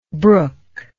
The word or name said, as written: brookable